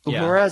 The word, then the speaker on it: Uhura: Uhura, is that how you say her name? – Right.